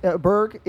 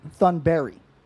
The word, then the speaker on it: Thunberg: Because did you know that Greta Thunberg, Berg, that's not how you say that at all. – How do you say it? – Thunberry. – Thunberg? – Yeah. – Oh, okay, that makes sense. The G is silent.